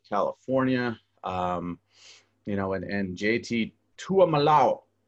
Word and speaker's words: Tuiamoloa: Tuiamoloa.